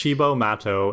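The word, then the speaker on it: Cibo Matto: So that night, Chibo Mata, I forgot, I don't even know how to pronounce it. Yeah, it's Chibo Mato. It means crazy food in Italian.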